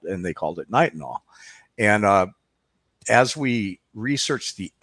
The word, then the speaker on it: Nitinol: Nitinol. Is that correct? – Nitinol.